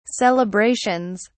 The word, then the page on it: celebrations: British English